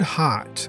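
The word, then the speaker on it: haat: The word hot means bizarre in Hindi and is pronounced similarly to the English word heart, which makes the name of the exhibition a play on words.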